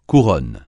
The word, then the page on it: couronne: French